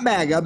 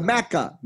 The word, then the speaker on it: Macca: Not MAGA, MACA. – MACA, Make America Crimson and Clover Again.